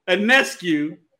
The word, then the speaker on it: Ionescu: Ionescu from the New York Liberty.